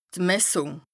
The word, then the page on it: d' Messùng: Bas Rhin